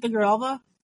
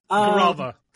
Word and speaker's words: Grijalva: How do you say her name again? I've heard it like a couple times. – Adelita Grijalva. – Grijalva. The last name was the one I was looking for.